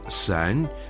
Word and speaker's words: 三: Слово «сань» произносится первым тоном, означает «три».